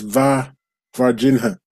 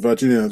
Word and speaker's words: Varginha: This Varginha is spelt Ver, as in virgin, isn't it? – A V-A-R, Varginha. Is it? – Is that V-A-R? I thought it was V-I-R, V-I-R, Varginha. – Yeah, Varginha, and then H-A. – V-A-R. Yeah. – So instead of virgin, you've got an A instead of the I, so it's Varginha in Varginha.